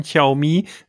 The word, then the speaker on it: Xiaomi: Und jetzt kommt dann immer, wie spricht man es aus? Xiaomi. Du darfst. – Wahnsinn. Ja, richtig. Ich glaube, so soll es sich aussprechen. Xiaomi.